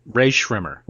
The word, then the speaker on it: Rae Sremmurd: Did I pronounce it wrong according to your opinion? – Ray Shrimmer. – Ray, Ray Shrimmer. Shrimmer. I, I, Rae Sremmurd. – Rae Sremmurd. Rae Sremmurd. Yeah.